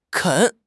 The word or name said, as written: 啃